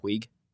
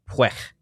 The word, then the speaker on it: Puig: He doesn't pronounce his last name Puig? – Really? – Yeah, it's like Puig.